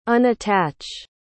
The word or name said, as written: unattach